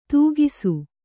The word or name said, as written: ತೂಗಿಸು